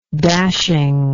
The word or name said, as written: dashing